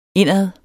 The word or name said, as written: indad